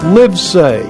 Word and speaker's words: Livesay: Livesay. My last name is spelled Live, L-I-V-E, Live, and Say, S-A-Y, Say.